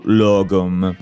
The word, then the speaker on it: lagom: E quindi ecco che anche anche qua interviene il nostro caro amico Lagom, o Logom, vi ricordo che la pronuncia in realtà sarebbe quella, ma non, non...